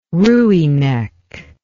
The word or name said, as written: rooinek